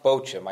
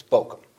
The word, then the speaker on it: Bochim: So it's not Bochim. I did look it up. Like, it's not a CH as in church, but a CH as in character. So it's Bochim.